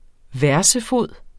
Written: versefod

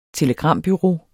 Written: telegrambureau

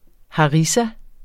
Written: harissa

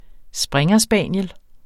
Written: springerspaniel

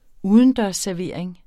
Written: udendørsservering

